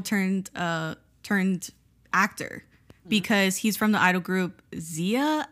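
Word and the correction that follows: ZE:A: and I don't think I'm pronouncing that right. – Zaya. So we actually got into this because MC Won is from Zaya as well. – Okay. – So we had one night where we actually had to look it up. Looked it up. Zaya.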